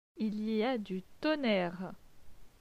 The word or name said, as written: Il y a du tonnerre